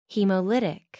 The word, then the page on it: haemolytic: American English